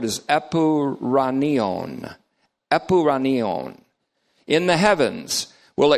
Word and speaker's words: epouranion: Epuranion is E-P-O-U-R-A-N-I-O, omega O, N. Epuranion. Looks like this if you put it in English uncials. E-P-O-U-R-A-N-I-O-N, long O.